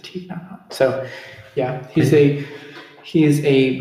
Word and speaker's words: Thich Nhat Hanh: Thich Nhat Hanh. – Thich Nhat Hanh? – Yeah.